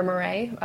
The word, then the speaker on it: Morey: One that I like a lot is from Pierre Moret. Pierre? – Moret? – Moret. – Spell it. – M-O-R-E-Y. – Okay.